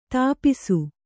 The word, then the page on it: ತಪಸಿ: Kannada